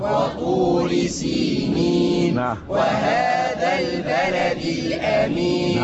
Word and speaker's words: سينين: أقول سينين، أكيد في خطأ بيحصل، ما تشغلش بالك إيه اللي بيحصل بالخطأ. أنا النهاردة يهمني اليوم، يهمني النطق الصحيح. خلاص الصحيح سينين.